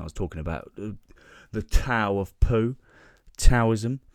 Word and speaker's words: Tao: It's spelt Tao, said Tao. I didn't say Tao until a few days ago until someone corrected me.